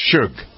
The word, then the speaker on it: Suge: Is that how you pronounce it? It's just Suge.